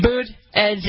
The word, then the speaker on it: Buttigieg: Boot, edge.